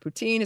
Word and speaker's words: poutine: First, in French, or at least Quebec French, the word poutine is pronounced the same as the ruler poutin.